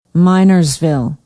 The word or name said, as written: Minersville